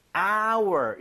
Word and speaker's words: hour: 이게 hour 묵음이 아니라 예. 네. – hour 이렇게 발음이 되는 거예요. – 아. – 발음 자체가 모음 사운드예요.